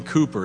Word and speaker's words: Cowper: A few weeks ago, I mentioned a great hymn writer named William Cooper. His name is spelled C-O-W-P-E-R, so many times people pronounce it Cowper, but it's William Cooper.